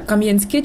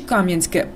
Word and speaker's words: Кам'янське: Містові повернули історичну назву, однак як вона звучатиме – Кам'янське чи Кам'янське – поки невідомо. Столичний історик Володимир В'ятрович переконаний, що правильний наголос на останньому складі.